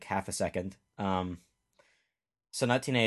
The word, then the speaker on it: Sonatine: And we're here with our second movie, Sonatine. Which, I guess, corrections department, last episode we pronounced it Sonatine.